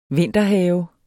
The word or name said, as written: vinterhave